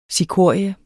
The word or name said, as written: cikorie